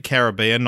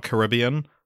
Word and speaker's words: Caribbean: And just off the bat, I'm going to be pronouncing it Caribbean, not Caribbean.